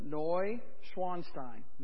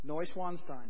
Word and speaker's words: Neuschwanstein: The castle is called, not like you think it is, it's spelled N-E-U. That is not new. In German, that rhymes with boy. So, Neu, right? Neu. Swan, like swan with the S, because it basically means swan stone. So Neuschwanstein. Neuschwanstein.